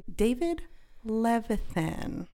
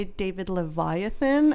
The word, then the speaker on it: Levithan: This week we're talking about Two Boys Kissing by David Levithan. I kept wanting to say David Leviathan, and I was like, no, I don't think that's it.